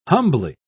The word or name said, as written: humbly